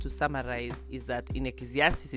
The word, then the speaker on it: Ecclesiastes: So Ecclesiastes it is. Ecclesiastes, yes, that's how it's pronounced.